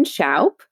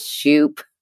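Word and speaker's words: Shoup: So yes, my name is Aaron Schaup, and it's S-H-O-U-P, just like shout, but with a P is my easiest way to tell everybody how to say my name, because 99.9% of people get it wrong. And everybody says Schaup, but that's okay.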